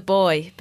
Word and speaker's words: buoy: And it's called The Boy, but it's spelled B-U-O-Y.